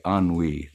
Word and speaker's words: ennui: And then I asked Julia, how do you pronounce that word ennui, E-N-N-U-I? She says, oh, that's ennui. It's a French word.